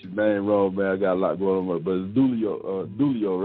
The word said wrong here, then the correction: Dooley: right? – Dooley. – Yeah. Dooley. Okay.